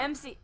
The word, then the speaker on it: emcee: E M C E E. – Yeah, MC. Yeah. – MC like MC. Not what? How did you say it? – EMC. – It's MC.